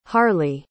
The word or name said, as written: harley